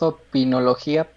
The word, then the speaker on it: Opinología: Así es, es Opinología Podcast con acento en la I.